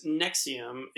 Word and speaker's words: NXIVM: This is the way they pronounce it is Nexium, even though it's spelled NXIVM.